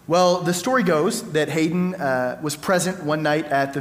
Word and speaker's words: Haydn: I was saying Hayden, but I was corrected right before the service that it's Haydn, I think.